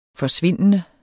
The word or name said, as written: forsvindende